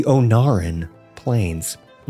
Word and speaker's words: Ohn'ahran Plains: The Ohn'ahran Plains. Mm-hmm. That's how you pronounce it, I think. that sounds right.